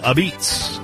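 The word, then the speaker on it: apizza: People will call it New Haven style a beats, is how they say it.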